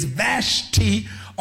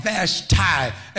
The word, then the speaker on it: Vashti: And this gentleman has a queen whose name is Vashti or Vashti, as various ones pronounce it.